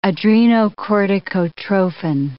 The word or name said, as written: adrenocorticotrophin